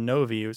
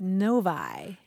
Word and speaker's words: Novi: We heard from Frank Maynard, who's in Northern California, and the city that he lives in is spelled N-O-V-I. Any thoughts about how... – I don't know. Navi or Novi seemed like the logical ones, but what is it? – Novi. – Novi. – Isn't that weird? – Maybe it's like Lodi, L-O-D-I.